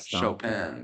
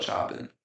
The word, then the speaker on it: Chopin: We know how to say it, by the way. – Yeah, Chopin. But we call it Chopping.